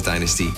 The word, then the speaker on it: DNSTY: They're from Germany, and it's actually spelled as D-N-S-T-Y, so I presume it's pronounced as Dynasty.